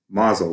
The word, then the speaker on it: Mosel: There's the, and I'm probably going to not say this right, the Alsace, A-L-S-A-C-E, yep. – Or Mosel, Mosel? – Mosel.